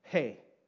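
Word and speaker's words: Hey (ἡ): And that definite article in the Greek, it's pronounced like this. Hey. Say that with me. Hey. Very simple. H-E-Y. Hey.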